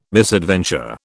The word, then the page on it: misadventure: British English